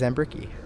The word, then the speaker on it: Zambricki: My name is Zambricki.